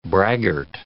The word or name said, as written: braggart